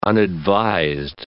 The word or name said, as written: unadvised